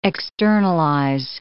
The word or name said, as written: externalize